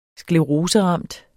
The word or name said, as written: SKLEROSERAMT